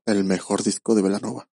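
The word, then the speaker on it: Belanova: Y el problema aquí, que cuando tocaban en estos bares de Guadalajara, donde normalmente se presentaban, la gente lo pronunciaba con la doble L, o uh-huh, sea, Bellanova, ¿no? – Okay. – Entonces decidieron, pues, qué importa cómo se escriba, para que se pronuncie como el origen italiano, y decidieron quitar una L y quedar simplemente con el nombre actual, que es Bellanova.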